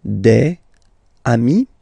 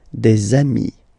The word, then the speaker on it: des amis: And if you make the liaison, so the link between the two, you will get des amis, okay? Des amis. So amis, remember, doesn't change. Even if you get to write the S, then you don't pronounce it, okay?